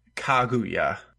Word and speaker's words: Kaguya: Kaguya.